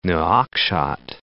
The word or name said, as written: Nouakchott